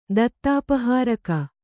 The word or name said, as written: ದತ್ತಾಪಹಾರಕ